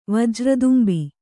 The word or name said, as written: ವಜ್ರದುಂಬಿ